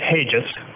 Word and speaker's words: Hages: It's Hages. – Hages, two syllables with a soft G.